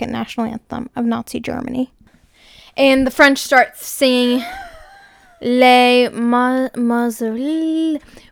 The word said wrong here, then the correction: La Marseillaise: Hi, it's a year later, here to correct my pronunciation. It's Les Marseillaises.